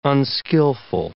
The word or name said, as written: unskillful